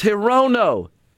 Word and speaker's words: Hirono: Horino. It's in Nevada. Hi, Reno. It's Hirono. And the senator, I'm not a big fan, but it is a she. It's Maisie Hirono.